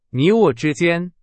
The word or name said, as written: 之间